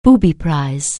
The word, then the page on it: booby prize: anglais